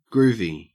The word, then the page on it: groovy: Australian English